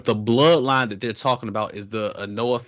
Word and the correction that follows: Anoa'i: family. – The Anoa'i family. – Oh, is that how you pronounce it? – Yeah. – Okay. – Pronounce that one more time. – Anoa'i.